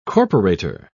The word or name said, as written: corporator